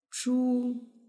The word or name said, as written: zhu